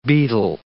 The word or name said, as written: beetle